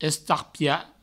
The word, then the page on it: estarpiar: Provençal; estarpia